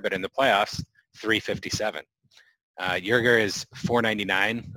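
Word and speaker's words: Joerger: They mentioned the Lakers assistant, Jason Kidd, the Hawks interim coach and former Blazers head coach, Nate McMillan, Philadelphia 76ers assistant, Dave, is it Yerger? Did I say that right? I think so, yeah.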